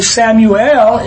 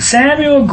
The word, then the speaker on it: Samuel: And Samuel grew, Samuel, not Samuel. It's three syllables.